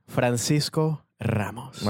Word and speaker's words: Francisco Ramos: You want me to pronounce it like Spanish accent? – Traditionally, yeah. – Francisco Ramos.